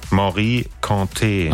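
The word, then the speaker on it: Mory Kanté: Wie wird's ausgesprochen? – Morikante. – Ach so, guck mal. Das war also falsch.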